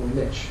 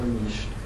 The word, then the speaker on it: niche: The last day, this is a picture from the last day in the first year of this program, they talk about ecological niche, or niche, and how they like to pronounce it. Is there a right pronunciation? – There are two right pronunciations. – What's that? – There are two correct pronunciations.